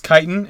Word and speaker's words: chitin: I was like, I'm not, if you didn't know, I was going to say it's probably chitin. – Yeah, it's chitin, and I even put the word kite, K-I-T-E, yeah. – dash I-N, so that I would know how to pronounce it.